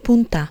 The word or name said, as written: punta